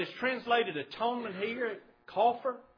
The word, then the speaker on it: kaphar: It's the Hebrew word, and it's spelled K-A-P-H-A-R, and it's actually pronounced coffer. Coffer.